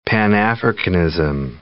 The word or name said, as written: Pan-Africanism